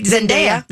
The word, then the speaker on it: Zendaya: So Zendaya is definitely leaving Disney behind with this role. And we love her. – I do love her. – Are we saying her name right? Zendaya.